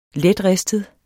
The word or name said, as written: letristet